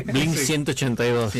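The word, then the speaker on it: Blink-182: Bien, estamos hablando de Blink 182, ¿lo dije bien? – Blink 182.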